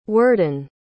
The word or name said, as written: worden